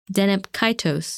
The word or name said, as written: Deneb Kaitos